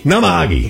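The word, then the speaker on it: Namahage: Namahagi. – Okay. – I think I got the pronunciation right. – It sounds pretty good.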